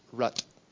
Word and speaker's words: Rith: His name is spelled R-I-T-H, but pronounced Rut.